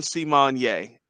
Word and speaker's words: Symoné: It's pronounced See-Mon-Yay. It says it on the screen now. I see it. So, yeah. So, her name is Raven See-Mon-Yay.